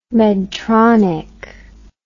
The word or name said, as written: medtronic